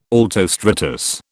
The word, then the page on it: altostratus: British English